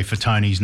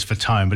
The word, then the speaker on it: Fatone: When I first saw Joey Fatone's name, I know he says Fatone, but it's Fatone is the way it's supposed to be pronounced.